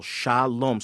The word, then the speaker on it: shalom: Many may recognize the Hebrew word shalom, but if you use the transliteration, it would be shah, S-H-A, then we'd separate it, lom, L-O-M-E, for example, shalom.